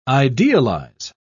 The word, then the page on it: idealise: British English